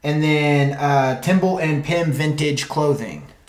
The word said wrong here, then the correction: Tibble and Pim: Timble and Pim. – Did I say that right? – Tibble and Pim, yeah.